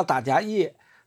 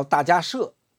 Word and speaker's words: 大迦叶: 叫迦摄佛，就是大迦叶，不叫大迦叶，他正确的发音叫大迦摄。